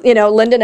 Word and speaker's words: Lynnda: But it is, Linda Nelson, and my name is spelled LYNNDA because Nelson is a very common last name.